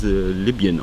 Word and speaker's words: Libyen: Danach ging es um, wie ich sage, Libyen, wie du sagst, Libyen und wie ich erfahren habe, dass es Libyen ausgesprochen wird.